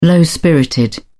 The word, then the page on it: low-spirited: British English